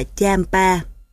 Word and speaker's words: Champa: Champa đọc là Champa.